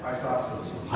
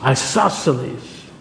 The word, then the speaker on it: isosceles: Isosceles. – Isosceles.